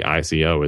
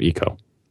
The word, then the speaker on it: ICO: It's just strange that the American default pronunciation when they see ICO is Ico instead of Eco.